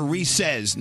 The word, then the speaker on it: Reese's: They're Reese's, not Reese. Why? – Rhyme with feces. I know you love that. – Why do you and Nate call them Reese's? They are Reese's. You do it too? – I do it too. – Reese's does not rhyme with feces. – But it rhymes with PC's.